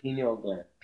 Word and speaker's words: pineal: On the brain, the how do you pronounce it? Pineal gland? – Pineal gland. – Pineal gland.